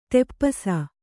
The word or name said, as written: ತಪಸಿ